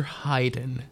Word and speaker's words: Haydn: My first period class was called Theories of Theories, and it was taught by a man named Mr. Haydn, spelled and pronounced like the musician.